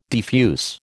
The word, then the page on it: defuse: American English